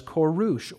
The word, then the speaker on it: Koresh: The child's name in Persian was Koresh, or Cyrus is the Greek pronunciation.